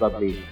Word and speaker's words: Bublé: It's pronounced Bublé.